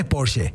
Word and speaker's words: Porsche: Vos sabés que sin miedo a decir Porsche, ¿eh? La pronunciación es Porsche.